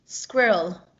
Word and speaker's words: squirrel: No, but I do appreciate that you finally pronounced that word correctly as an English lady, because in America we say squirrel, but as an English lady you say squirrel. – That's right, squirrel.